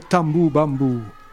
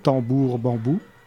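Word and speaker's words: tamboo bamboo: Donc la première réponse musicale à ça, c'est le tambou bambou. Alors ça c'est de nouveau une prononciation anglaise de deux mots très prononçables en français, tambour bambou.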